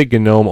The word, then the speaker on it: GNOME: I say GNOME only because I talked to someone more than one person who works on the GNOME Foundation, and they say that's how it's pronounced.